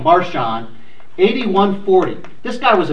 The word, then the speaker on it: Marcion: Well, in the middle of the second century, there was this guy called Marcion, or usually pronounced Marcion in English.